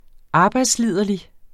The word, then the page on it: arbejdsliderlig: ˈɑːbɑjds-